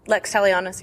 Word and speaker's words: Lex Talionis: We're here at Balticon 49 with Starla Hutchton, author of the Endure series, the Evolution trilogy, and the recently released Lex Talionis. Did I pronounce that right? – Lex Talionis, yeah.